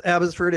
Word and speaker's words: Abbotsford: Uh, Ken lives in Abbotsford, British Columbia. Did I pronounce that correct? It's Abbotsford.